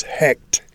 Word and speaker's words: Heqt: Their name for their frog god was Hekt. H-E-Q-T would be the way you'd spell it in English.